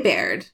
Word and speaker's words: Baird: Okay, Baird. Please correct me if I'm saying that incorrectly. B-A-I-R-D. – Maybe Baird. – Baird, yes.